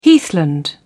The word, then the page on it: heathland: British English